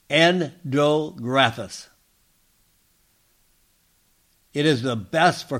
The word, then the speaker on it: Andrographis: This plant is called Andrographis.